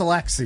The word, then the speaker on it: Aliaksei: A-L-I-A-K-S-E-I. – I mean, that's to me, that's Alexei.